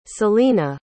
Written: salena

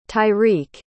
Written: tyreke

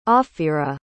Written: ophira

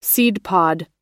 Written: seedpod

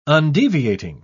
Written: undeviating

